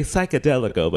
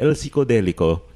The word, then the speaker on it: El Psicodélico: So kind of like psychedelic with an O at the end. – Right. – You can say psychedelico, but El Psicodélico is the way that it's pronounced.